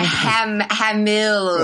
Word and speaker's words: Hamill: Yeah, Hamill.